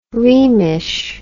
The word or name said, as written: rhemish